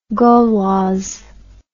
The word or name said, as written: Gauloise